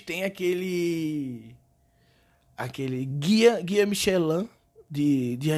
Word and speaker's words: Michelin: Eu não sei também se a pronúncia correta é Michelin, Michelin. Aí é francês, aí é mais complicado, é Michelin. Michelin não é nem fudendo.